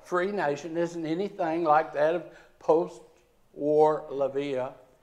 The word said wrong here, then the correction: Latvia: Hope I'm pronouncing that right. But Latvia, thank you very much. Latvia.